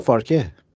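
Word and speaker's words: Farke: فارکه؟ – فارک اینجا میگن بهش، فارک.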